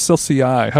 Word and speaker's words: Celsius: It's pronounced Celsius.